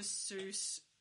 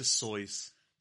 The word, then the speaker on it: Seuss: So yeah, Dr. Seuss, it should rhyme with voice. So it is Dr. Seuss.